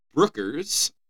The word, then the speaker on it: Brooker: Charlie Brookers. Right? Am I saying that right? – Oh, yeah, I'll go with that. It Char- says Brooker. – Yeah, yeah, Brooker.